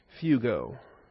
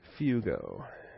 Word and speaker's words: fugo: Fugo. Rhymes with Yugo, which you wouldn't want to be driving if you're trying to flee from anybody. Fugo.